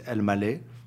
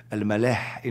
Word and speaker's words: Elmaleh: By the way, there's an interesting story about my last name, which is Elmaleh, pronounced in the French way, Elmaleh in Arabic.